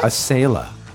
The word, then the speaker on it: Acela: Azalea? – What? – Acela.